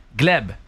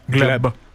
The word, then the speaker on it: Gleb: Gleb, c'est comme ça qu'on prononce son nom. – Gleb.